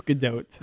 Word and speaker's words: Gadot: Gadot. I said it wrong. – I think it's Gadot.